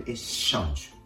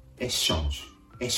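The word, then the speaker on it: échange: てここも小さなポイントですけれども、シャ、シャ、エッシャンジュになるところですね。英語ではエクスチェンジ、シャの音でしたが、フランス語でエッシャンジュ、シャの音になる。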